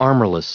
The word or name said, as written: armorless